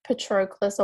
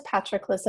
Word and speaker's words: Patroclus: Yeah, it's either like Patroclus or Patroclus, but Brad Pitt says Patroclus in the movie, so I think you should follow Brad Pitt's lead.